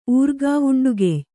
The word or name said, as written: ಊರ್ಗಾವುಂಡುಗೆಯ್